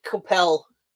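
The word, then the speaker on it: Coipel: This is Wolverine 301 by Olivier Coppel. – I pronounce it Coppel. Until anyone's corrected me, and no one has so far. So I'll accept your pronunciation.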